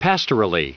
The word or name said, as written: pastorally